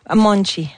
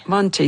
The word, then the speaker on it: Monte: On dit Monte? – Monchi. – Monchi, ça s'écrit M-O-N-T-E pour les intéressés.